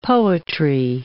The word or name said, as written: poetry